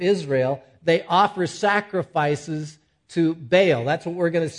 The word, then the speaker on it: Baal: Now, I have a hunch that Balaam, and Balak for sure, King Balak of the Moabites for sure, was a Baal worship. Some people pronounce that idol as Baal. It's easier for me to say Baal, because if Baal comes around, you need to bail.